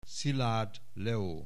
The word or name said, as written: Szilard Leo